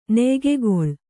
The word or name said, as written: ನೆಯ್ಗೆಗೊಳ್